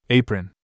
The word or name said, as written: apron